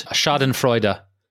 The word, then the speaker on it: Schadenfreude: And the subject of that documentary that I'm most excited to talk about with you is it Schadenfreude? Schadenfreude? – Yes. – How you pronounce it? – Yes. Schadenfreude.